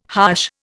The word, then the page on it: harsh: Indian